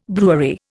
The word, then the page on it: brewery: Indian